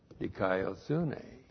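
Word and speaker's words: dikaiosune (δικαιοσύνη): Now, you know what the word for righteousness is, dikaiosune. D-I-K-A-I-O-S-U-N, long E. That's the word righteousness, dikaiosune. We don't have dikaiosune here. We have Euthutes. E-U-T-H-U-T, long E-S.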